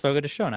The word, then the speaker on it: Fogo de Chão: It's pronounced Fogo de Chão. I used to do ads for them, and they made sure I pronounced it correctly.